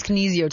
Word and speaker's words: Kinesio: Did I say that right? Kinesio tape. – It's Kinesio tape.